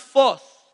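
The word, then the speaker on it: phos (φῶς): It's pronounced phos, F-O-C-E.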